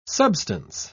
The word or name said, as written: substance